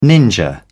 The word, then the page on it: ninja: British English